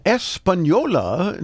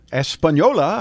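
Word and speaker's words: Española: Espanola, New Mexico.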